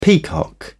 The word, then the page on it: peacock: British English